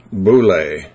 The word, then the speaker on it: boule: One root is thelo, T-H-E-L, long O, which means wish, will, or desire. The second root is boule. You'd spell it B-O-U-L long A, or in the dictionary long E.